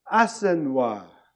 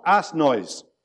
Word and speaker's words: Assenois: Assenois. Not ass noise, which is what it got called.